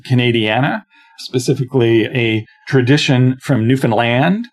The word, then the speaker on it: Newfoundland: And by the way, I said that right for those of you who are from Newfoundland. It's the accent is on the land, not on the new, and certainly not Newfoundland.